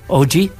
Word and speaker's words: Og: Yeah, OG. – Original gangster. – Right. Well, you spell it OG, but it's pronounced Og.